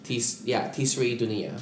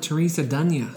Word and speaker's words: Tisri Dunia: Like I have had the pleasure of working with Siegel, Infinite Theater, Tisri Dunia, and of course recently with that's how you say it. – Tisri Dunia. – I've always called it Teresa Dunia.